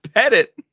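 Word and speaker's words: Pettitte: Andy Petit is your pitching coach. – Petit? – Michael Young. – Did you say Andy Petit? – Is that not how you pronounce it? – It's Pettit.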